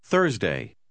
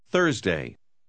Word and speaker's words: Thursday: Listen and repeat. – Thursday. – Thurs. Thurs. Thursday. – ¿Fue su pronunciación parecida a la del locutor? Diga, hoy es jueves. Tenga cuidado con el sonido thur.